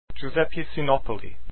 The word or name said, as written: Sinopoli